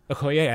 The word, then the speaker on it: Okoye: And she's also she's so powerful, and I love Oki's line after she has her kick-ass battle scene. – Okoye, I don't know how to pronounce her name.